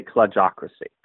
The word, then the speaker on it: Kludgeocracy: Yeah, I think it's a silent E. I usually say Kludgeocracy.